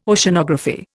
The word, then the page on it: Oceanography: Indian